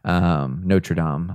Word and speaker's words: Notre Dame: Notre Dame. I don't know how you pronounce that. – Yeah, that's right. That's better that time.